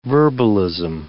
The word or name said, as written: verbalism